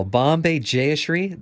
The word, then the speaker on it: Bombay Jayashree: Bombay Jayashree, the vocalist.